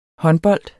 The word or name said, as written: håndbold